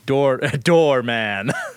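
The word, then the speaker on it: Dormin: Dorman, is that how you pronounce it? – Doorman.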